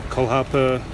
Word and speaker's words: Kolhapur: can I pronounce this right, the Kolhapur Supporters Club in India? – Yes, that's right.